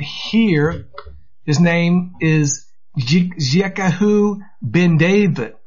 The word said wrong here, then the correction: Jiziahu: It is Yitzyahu Ben David. And it is spelled J-I-Z-I-A-H-U.